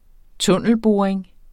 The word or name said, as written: tunnelboring